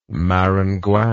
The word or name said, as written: maringouin